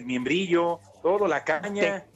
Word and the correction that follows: membrillo: tejocote y membrillo, güey. Pronuncia correcto.